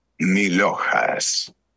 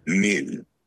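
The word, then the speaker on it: milhojas: Milojas. Now, let's split the word into syllables and then pronounce each syllable one by one. – So seductive. – Mil-o-has. – Mil-ho-has. – It's like Milhouse.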